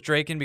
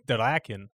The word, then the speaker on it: Draken: Because I thought it was Draken because they only use 1K instead of 2, and I just don't know these pronunciations. – The Japanese pronunciation is Draken.